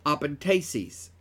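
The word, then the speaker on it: epenthesis: Apentesis is how it's pronounced.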